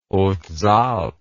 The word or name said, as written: hautes alpes